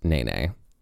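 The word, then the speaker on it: Nēnē: And it is pronounced Nene.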